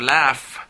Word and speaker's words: laugh: La número 4, laugh, el último sonido es f. Pero, profesor, pero acaba en gh. Sí, pero recuerden, no como lo lean, sino cómo se pronuncia.